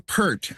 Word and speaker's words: Peart: I've been saying Neil Peart, as in Pearl. – Yeah. – It's Peart.